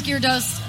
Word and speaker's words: Gyarados: Yes, I know it becomes Gyarados.